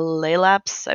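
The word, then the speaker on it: Laelaps: So the Raiju is the frigate, the Laylaps, I believe is how you pronounce it, the cruiser. – That's correct.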